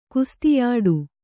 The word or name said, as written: ಕುಸ್ತಿಯಾಡು